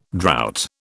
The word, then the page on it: drought: British English